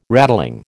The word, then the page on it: rattling: American English